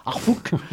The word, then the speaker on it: Harfouch: magnifiquement interprétée par Corinna Harfouch. Je ne sais pas comment on prononce, c'est une Allemande. Harfouch, voilà.